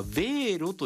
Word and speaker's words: Wero: ドイツ公共放送 NDR よりデジタル主権は市民にも、欧州発決済サービス、これはヴェーロと読むそうです、 WERO 広がるということで、これ教えてください。